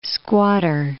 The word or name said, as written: squatter